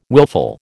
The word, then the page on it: willful: American English